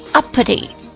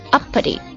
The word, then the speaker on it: uppity: Up 加上一个 pity，但是呢它的读音完全不是想象中的那样。 Uppity Uppity，重音在第一个音节上面， Uppity Uppity U-P-P-I-T-Y Uppity Uppity，它是一个形容词，表示自大的、骄横的、傲慢的、自视甚高的。